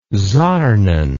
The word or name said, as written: sarnen